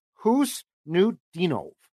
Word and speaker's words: Khusnutdinov: His name is spelled KHUSNUTDINOV. And I thought to myself, I got to find that pronunciation. I got no clue. According to Gord Miller, who calls the World Juniors for TSN, it's Husnudinov.